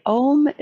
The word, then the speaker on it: Om: Human beings may reproduce it by extending and strengthening the open compound vowel sound, ow, with the nasal sound, mmm. The ow is generated deep in the body and is brought out through the nasal mm, which then resonates in the head.